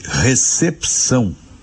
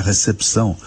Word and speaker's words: recepção: A gente escreve recepção e pronuncia recepção. A gente lê o que está escrito. Esse P de pato, diferentemente do que a gente diz no dia a dia, não é mudo. Ele é lido, ele é pronunciado. Em Portugal, esse P não é lido, né?